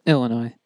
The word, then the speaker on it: Illinois: Do you just pronounce the S in Illinois? – Probably.